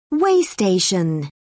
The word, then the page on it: way station: British English